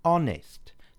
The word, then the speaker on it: honest: The four words that begin with a mute H. Our, air, honour, honest. So, an honest man.